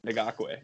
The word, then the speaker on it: Ngakoue: Ngakoue. Yannick Ngakoue. That's how you say it. It's close. That's pretty right.